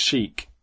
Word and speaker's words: sheikh: I'm going to say S-H-E-I-K-H. Am I right? – No final H, but yeah. – Okay. So is that rather than like a milkshake? That's that sort of shake. – Which apparently is pronounced chic in America.